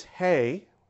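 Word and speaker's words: hey (ה): the way we pronounce it again, is hey.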